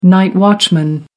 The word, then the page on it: night watchman: American English